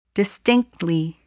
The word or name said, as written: distinctly